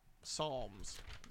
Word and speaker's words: Psalms: Frog Eyes with Violet Psalms. The P is silent.